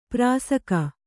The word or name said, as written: ಪಾರಸೀಕ